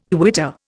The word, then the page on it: quitter: Indian